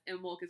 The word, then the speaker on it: Imbolc: But it means ewe's milk, if that helps with the pronunciation. Again, it's Imbolc. Those C's at the end always throw me off.